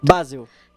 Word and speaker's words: Basel: PSG 3, Basileia 0. É isso, gente? Falei certo? – Basel. – Obrigada.